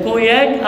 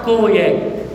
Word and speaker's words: كوياك: كوياك الاو واليوتا يتنطق يوتا، ممكن تنطق كوياك او كياك بس هي اصح كوياك، لكن الضغم يمكن في اليونان.